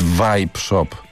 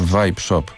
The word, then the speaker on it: Vibe Shop: Porozmawiamy i dowiedziałem się, jak się wymawia nazwę tego sklepu z wyrobami z nikotyną. To jest Vibe Shop. Ponieważ Vibe Shop.